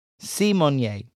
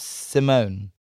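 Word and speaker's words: Symoné: Well, but it's actually Raven Simone, like Beyonce. Simone, not Simone.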